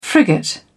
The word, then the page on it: frigate: British English